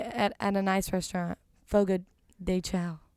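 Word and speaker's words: Fogo de Chão: I just wanted to It's Fogo de Chao.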